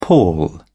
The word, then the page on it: pall: British English